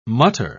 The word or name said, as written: mutter